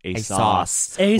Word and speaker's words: ASOS: Patty Harrison famously did a branded SponCon with ASOS, and she told me that they corrected her and said it's actually pronounced ASOS.